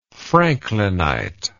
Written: franklinite

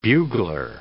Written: bugler

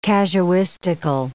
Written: casuistical

